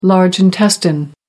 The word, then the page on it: large intestine: American English